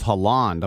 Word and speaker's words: Haaland: You mean Haaland. It's Haaland. I'm putting on the accent on the wrong syllable.